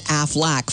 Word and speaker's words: Aflac: This is so fun because Ben Affleck and the insurance company Aflac finally are in an advertising partnership. – You know, just one question. Supplemental insurance. Why is it a duck? – Huh? It rhymes with quack.